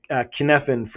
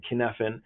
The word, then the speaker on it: Cynefin: And that's the Kinefin framework by David Snowden. And it's spelled, it's Welsh for, for a sense of place. And that Kinefin framework, the C-Y-N-E-F-I-N, Kinefin.